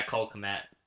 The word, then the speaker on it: Kmet: Yeah, Cole Kmet.